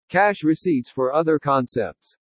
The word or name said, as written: CASH RECEIPTS FOR OTHER CONCEPTS